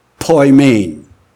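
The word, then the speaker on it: poimen (ποιμήν): It is pronounced poimen.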